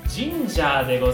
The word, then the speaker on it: Ginger: ともおのジンジャーでございます。 G-I-N-G-E-R でね、ジンジャーと読むんですけれども、翔太くんのメッセージとしてはですね、軽やかでポップなサウンドでウキウキワクワクする感じがあり、お出かけ欲がかきたてられます。